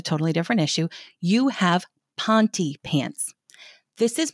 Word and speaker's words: Ponte: It is spelled P-O-N-T-E and it is pronounced Ponte. You do not have pont pants. You do not have point pants. You do not have pointy pants.